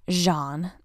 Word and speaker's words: Geanne: It's spelled G-E-A-N-N-E, but it's pronounced Jean.